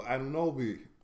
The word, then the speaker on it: Anunoby: How you pronounce his name? Oh, Anunoby.